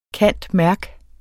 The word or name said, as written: cand.merc.